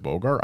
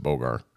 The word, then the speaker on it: Bogar: Is it Bogar? I've always said Bogar. – Yeah, that's correct. – All right, cool. Like Humphrey without the T.